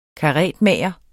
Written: karetmager

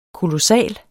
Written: kolossal

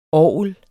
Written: ORGLER

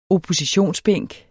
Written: oppositionsbænk

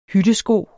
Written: hyttesko

